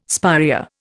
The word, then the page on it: spirea: Indian